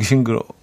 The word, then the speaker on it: 싱그러워: 싱그럽다, 징그럽다. 약간 그 중간 정도의 느낌으로 제가 발음 했군요. 아, 싱그러워. 약간 요런, 싱, 싱, 싱하고, 싱하고 약간 고, 싱, 싱그러워.